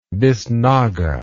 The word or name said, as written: bisnaga